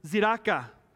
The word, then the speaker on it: tzedakah: The next one, the other word was tzedakah. And it's spelled with a T, but that's silent.